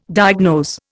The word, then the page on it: diagnose: Indian